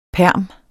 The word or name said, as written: perm